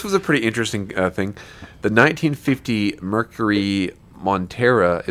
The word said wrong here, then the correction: Monterey: is that how it's pronounced? Monterey? – I think Monterey. It's Monterey Coupe.